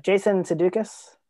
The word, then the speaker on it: Sudeikis: It starts on Jason Sudeikis.